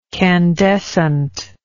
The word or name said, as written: candescently